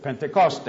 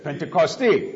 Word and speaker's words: Pentecoste: God's church will celebrate Pentecost, or as my good friend George Pantelidis back there told me to correctly pronounce it, Pentecoste. Pentecoste.